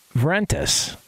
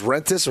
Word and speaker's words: Vrentas: Wait, you don't pronounce the V in her name? Uh, Vrentas. Isn't that? Oh, you're, you? are saying Vrentas. Yeah. – Is it just Rentis or Vrentas? No, Vrentas. – Mm. Yeah, with the V.